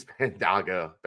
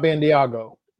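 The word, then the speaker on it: Bandaogo: But first off, man, he's Bandago. It's Bandago, I think. I waited till tonight to hear the official pronunciation over the loudspeaker. Aziz Bandago. – So, not Bandiago. – Bandago. Bandago. – I'm guilty. I'll put my hand up. I'm guilty of Bandiago.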